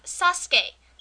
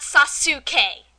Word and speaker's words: Sasuke: I've even heard them pronounce Sasuke, which is spelled S-A-S-U-K-E. It's Sasuke, not Sasuke.